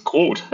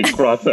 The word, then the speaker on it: Groth: So I'm Danish. So my name is Jens Grott. But especially in English speakers find that very difficult to pronounce. And I've given up, right? And I just say Groth as well.